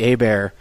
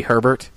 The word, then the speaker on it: Hebert: it's spelt Herbert, it's spelt Bobby Herbert, but it's pronounced Hebert, you know, because it's French.